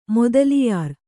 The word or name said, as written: ಮೊದಲಿಯಾರ್